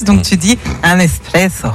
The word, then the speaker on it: espresso: Alors tiens, est-ce que tu dis un espresso ou un expresso ? – Pour le café ? – Ouais. – Espresso. – Espresso, ouais. Étymologiquement, on ne prononce pas le X. L'origine du mot vient de l'italien esprimere.